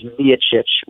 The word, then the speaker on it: Miocic: So long story short, this is one that we've been battling for a while and will continue to battle, but the Croatian pronunciation, the way he says it on tape for us, is Miocic with the emphasis on the first syllable.